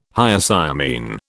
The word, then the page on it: Hyoscyamine: British English